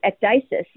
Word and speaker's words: ecdysis: Ectasis. Is it ecdysis or ectasis? – I always, in class, always pronounced it ecdysis. But I'm going with you.